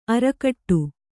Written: ಆರ್ಕಾಟಿ

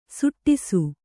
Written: ಸುಡಿಸು

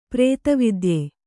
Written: ಪ್ರೇತವಿದ್ಯೆ

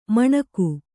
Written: ಮಣಗು

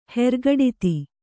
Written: ಹೆರ್ಗಡಿತಿ

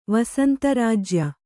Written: ವಸಂತರಾಜ್ಯ

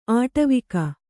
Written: ಆಟವಿಕ

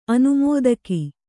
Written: ಅನುಮೋದಕಿ

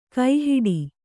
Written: ಕೈಹಿಡಿ

